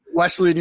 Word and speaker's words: Wesleyan: Wesleyan University.